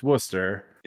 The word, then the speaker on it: Worcester: No, or it's like, it's pronounced Worcester.